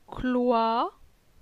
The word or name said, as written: Cloi